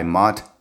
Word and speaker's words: Monte: Matthews can be found in the volume titled The Joseph Smith Translation, pages 300 and 301, edited by Mont S. Nyman. It's spelled M-O-N-T-E, but he pronounces it Mont S.